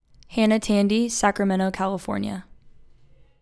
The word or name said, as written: Hannah Tandy